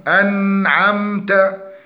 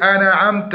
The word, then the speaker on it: أنعمت: فنقول الذين أنعمت، فمن اللحون عدم تحقيق نطق همزة القطع، فيقول الذين أنعمت، فيجب أن نحقق الهمزة، وعدم تحقيق الإظهار بين نون وعين. أنعمت قلقلة النون، فيقولون أنا عمت، وهذا خطأ.